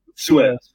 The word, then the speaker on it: Suez: Suez.